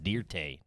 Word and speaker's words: Dirt: It's actually pronounced Dirte. Don't try to church it up.